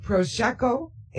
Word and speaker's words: Prosecco: You get a glass of Prosecco Am I pronouncing that right? – That's correct, yes.